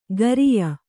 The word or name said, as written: ಖರಿಯ